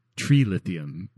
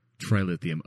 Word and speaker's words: trilithium: And he doesn't pronounce it like tree lithium because that he just sounds like an idiot. – Well, so that tree lithium. Yeah. No, Commander, it's called trilithium.